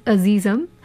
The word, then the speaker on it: Azizam: So ideally it should be pronounced as Azizam, but we hear it as Azizam.